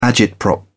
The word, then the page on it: agitprop: British English